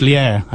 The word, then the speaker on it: Liège: The man, it's pronounced Liège, actually, but it's spelled Liège.